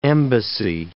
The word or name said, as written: embassy